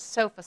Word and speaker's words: SOFA: Yeah, I was going to say, I'm assuming you pronounce it SOFA score. – Yes.